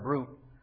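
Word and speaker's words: bruit: That word brute rhymes with fruit.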